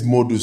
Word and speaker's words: Modou: So, uh, yeah, my name is Modu Say you know, and uh, actually it's spelled. I don't know if you know this. Not a lot of people know. It's spelled M O D O U.